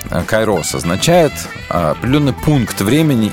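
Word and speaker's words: кайрос (καιρός): Это хронос и кайрос, или кайрос, если правильно. Хронос и кайрос, ударение будем ставить правильно.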